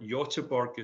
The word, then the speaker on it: Göteborg: B-U-A, which is halfway between uh, Gothenburg or Göteborg, as he would uh, tell me to pronounce it, yeah, yeah, yeah.